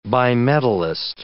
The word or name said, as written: bimetallist